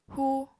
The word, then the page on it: hu: apatani